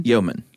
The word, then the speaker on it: Yeoman: But in between, there were yeomen. I think that's how you say it. Y-E-O-M-A-N. – Yeoman.